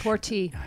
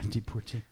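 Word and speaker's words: Deportee: We will have, of course, the music Deporté coming right up. Did I mispronounce that? – No, that's correct. – That's the Spanish Deporté. – Deporté.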